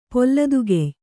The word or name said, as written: ಪಲಿತಕಾಯ